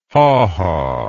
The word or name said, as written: hawhaw